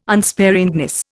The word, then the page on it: unsparingness: Indian